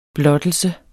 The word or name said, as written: BLOTTELSE